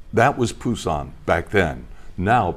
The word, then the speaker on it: Busan: I say Busan, but it's now pronounced, I guess, Busan, right? Busan. With a B. It used to be P.